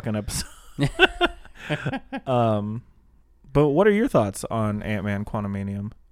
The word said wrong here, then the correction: Quantumania: Quantumanium? – I don't know. I've said it wrong like 6 times, so just go with it at this point, okay? Quantumania.